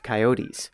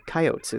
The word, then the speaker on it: coyotes: coyotes, or coyotes as folks pronounce it around here.